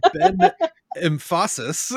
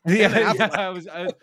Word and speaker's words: Affleck: It's not Ben Emphasis. It's Ben Affleck.